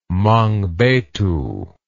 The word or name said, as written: mangbetu